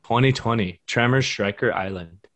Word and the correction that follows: Shrieker Island: I believe it's pronounced Shrieker Island.